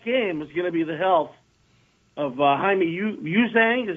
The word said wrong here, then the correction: Jaime Jaquez: Is that how you say it? – No. Jaime Jaquez.